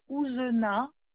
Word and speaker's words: Ouzena: la troisième personne, c'est madame Ouzena, comme ça se prononce avec un Z, D'accord.